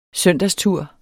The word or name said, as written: søndagstur